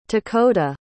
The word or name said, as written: takoda